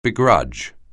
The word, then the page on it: begrudge: anglais